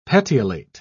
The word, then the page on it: petiolate: British English